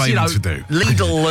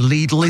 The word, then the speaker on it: Lidl: You do realise the I in Lidl is a German I, which, of course, is a long E sound.